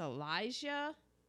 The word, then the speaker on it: Elisha: And then I'm guessing it's Elijah. Yeah, it's just not spelled the way Elijah usually is. It's mm-hmm. actually spelled phonetically. E-L-I-S-H-A.